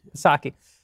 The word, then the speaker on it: Psaki: Psaki.